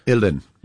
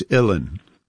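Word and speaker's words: uilleann: Ilin. – I'm sorry, what is it? – It sounds so much easier than it. Well, that's Irish, the Gaelic, you know? – Yeah. – It's Ilin. Like chillin', illin.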